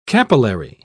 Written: capillary

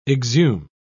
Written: exhume

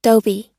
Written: dobe

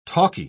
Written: talkie

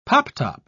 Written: pop-top

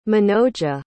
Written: manoja

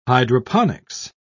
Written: hydroponics

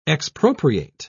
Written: expropriate

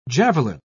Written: javelin

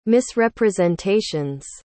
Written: misrepresentations

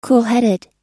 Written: coolheaded

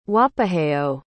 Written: wapaheo

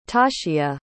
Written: tashia

